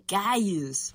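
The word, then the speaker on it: Kyuss: Cuse. No se dice Cuse, se dice Cayuse.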